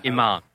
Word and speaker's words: Iman: Iman.